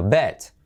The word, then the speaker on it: bet: No se dice beat, sino bet.